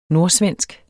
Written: nordsvensk

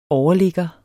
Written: overligger

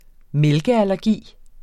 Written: mælkeallergi

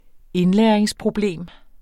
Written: indlæringsproblem

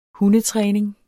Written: hundetræning

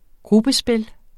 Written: gruppespil

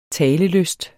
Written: talelyst

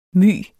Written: myg

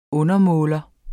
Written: undermåler

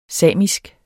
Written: samisk

